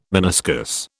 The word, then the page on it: meniscus: British English